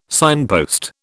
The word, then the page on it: signpost: British English